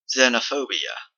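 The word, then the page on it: xenophobia: Canadian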